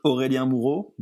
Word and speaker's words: Aurelien Moreau: I say it Aurelien Moreau, but I know it's hard from a non-French speaker.